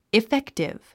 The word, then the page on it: effective: iféktiv; イフェクティブ